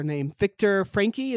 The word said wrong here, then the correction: Frankl: is that correct? – Frankl. – Frankl, thank you, Victor, yeah, Viktor Frankl.